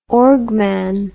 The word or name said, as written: orgman